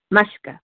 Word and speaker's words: Maashka: I spell it M-A-A-S-H-K-A, Mushka, and that's how I say it.